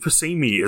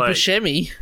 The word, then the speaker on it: Buscemi: Like, Steve Buscemi isn't a particularly handsome guy. Sorry, Steve, if you're listening, which I doubt, but just in case. – You mean Steve Buscemi? – However you pronounce it.